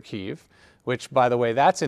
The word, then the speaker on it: Kyiv: I don't know if you've noticed on the news, the pronunciation and the spelling of Kiev has now changed to Kyiv, K-Y-I-V. And the reason is Kiev is the Russian pronunciation and Kyiv is the Ukrainian.